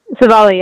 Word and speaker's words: Civale: Civale, yep.